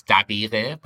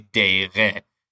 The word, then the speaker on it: dabire: I thought was interesting because just pronunciation-wise, sometimes you know, it's dabire, but if you're speaking colloquially, you'll say deire, deire, right. – or daire.